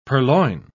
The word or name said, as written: purloin